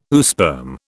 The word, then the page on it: oosperm: British English